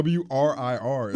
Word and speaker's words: WRIR: This is WRRLP 97.3 FM Richmond Radio. I hate that I'm Southern. Like, I don't know. WRIR is the is the correct pronunciation.